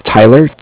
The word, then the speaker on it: Tyler: My name is Tyler, T-Y-L-E-R. And I'm calling with Cardiff. It's spelled C-A-R-V-I-F-F, as in Frank.